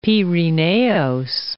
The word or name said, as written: Pirineos